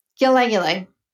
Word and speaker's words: ylang ylang: How do you pronounce that? Y-L-A-N-G. – Oh, ylang ylang.